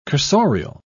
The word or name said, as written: cursorial